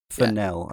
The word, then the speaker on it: Fennell: Fennell.